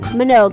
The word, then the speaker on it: Minogue: Minogue.